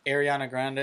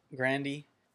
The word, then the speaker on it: Ariana Grande: Ariana Grande or whatever. – You said it, right? – Ariana. Anyway. – Grandy. – Sounds like you're ordering at Taco Bell.